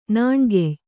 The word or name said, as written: ನೇಣ್ಗೈಯ